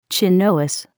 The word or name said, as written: chinois